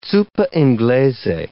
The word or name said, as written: zuppa inglese